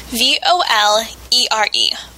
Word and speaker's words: volere: It means to want, and it's spelled V O L E R E, volere.